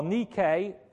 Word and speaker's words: Nike: Nike, or Nike, to be, I think, better pronounced in the Greek, Nike was the goddess of victory.